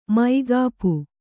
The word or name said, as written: ಮುಕೋಪು